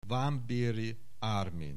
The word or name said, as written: Varnbery Armin